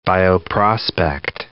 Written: bioprospect

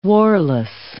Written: warless

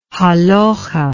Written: halakhah

